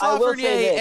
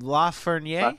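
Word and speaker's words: Lafrenière: Alex Lafreniere, and that's how you pronounce his name, Lafreniere.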